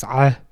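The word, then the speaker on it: ah (ع): You know, similarly, I've had linguists talk to me about, you know, the pronunciation of the pharyngeal consonant in Arabic, ayn, and how do we pronounce ayn, and it's ah.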